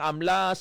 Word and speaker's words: AMLA: May iba siya nag-ahamba nga AMLAC, pero ang pronunciation is AMLA.